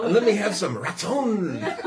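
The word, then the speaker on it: ratzon (רצון): And that comes from, that's the Hebrew word, it's you say it ratzon, is how you say it. – It sounds like rat. – Ratzon. R-A-W. Ratzon. Like you, Ratzon.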